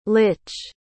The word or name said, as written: lich